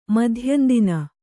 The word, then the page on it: ಮಾಧ್ಯಂದಿನ: mādhyandina